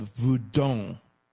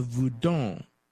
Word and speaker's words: Vodun: So the original West African voodoo is a polytheistic religion called Voudon. It's spelled Voudon, like V-O-D-U-N or V-O-U-D-O-U-N. And sometimes the last syllable isn't pronounced, like voodoo.